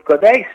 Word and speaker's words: Cotaix: Y discúlpame, capaz lo dije mal, pero ¿es así como se pronuncia Cotaix? – No, está perfecto. Bien. Está muy bien pronunciado. Cotaix, bueno, es un emprendimiento familiar.